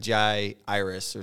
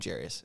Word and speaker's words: Jairus: And my wife says I say his name wrong. It's supposed to be like Jai Iris or something, but I call him Jairus. Just, you know, Indiana accent.